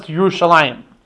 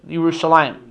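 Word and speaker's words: Yerushalayim (ירושלים): So it's spelled as if it was written Yeru and Shalem, but it's pronounced Yerushalayim, right?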